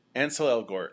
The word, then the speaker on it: Ansel Elgort: With, and I can't get his name right ever, and is it Ansel? – Ansel Elgort.